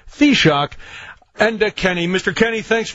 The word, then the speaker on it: Taoiseach: Yeah, if you take the first half of one of your former presidents, Theodore Roosevelt, take the T, and then shock, Taoiseach is the correct pronunciation. Shock.